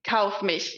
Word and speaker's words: Kaufmisch: Did I say that right? Kaufmisch. – Kaufmisch. Yeah, it's Kaufmisch. Very. It's tough to pronounce it if you're not German.